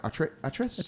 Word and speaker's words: Atreus: But I would say, and I always pronounce his name right, Atreus.